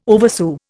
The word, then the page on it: oversew: Indian